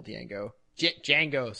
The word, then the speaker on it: Django: Django. Sorry, I keep getting that wrong. – The D is silent, as the trailer clearly states.